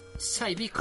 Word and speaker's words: Cybiko: Eh, ah, voy a ver si lo pronuncio bien, ¿eh? Saibico. Ah, muy bien, Saibico, Saibico. – Saibico, que parece, parece Sabeco, pero.